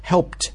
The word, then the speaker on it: helped: Helped，注意它读音 ed 啊，在这里因为前面那个 p 是清辅音，所以读的时候是 helped。啊，t 是 t sound， helped by spiking sales of a video advertisement。